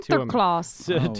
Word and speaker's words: Sinterklaas: The Dutch immigrants actually brought Sinterklaas, is how they pronounce it. – Sinterklaas.